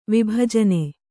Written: ವಿಭಜನೆ

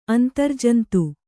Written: ಅಂತರ್ಜಂತು

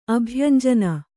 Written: ಅಭ್ಯಂಜನ

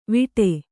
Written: ವಿಟೆ

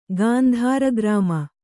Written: ಗಾಂಧಾರಗ್ರಾಮ

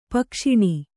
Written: ಪಕ್ಷಿಣಿ

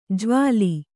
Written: ಜ್ವಾಲಿ

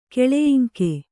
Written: ಕೆಳಯಿಂಕೆ

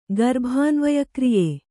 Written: ಗರ್ಭಾನ್ವಯಕ್ರಿಯೆ

